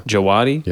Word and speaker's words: Djawadi: After that, we have Ramin Djawadi. I think is how you pronounce his last name. It's DJ. So kind of, I'm thinking djembe. – Yeah, yeah. – Djawadi.